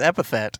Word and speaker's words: epithet: But if you say a racial epitaph? – I think it's epithet. – Epithet? – Yes. – I've been saying that wrong for a long time.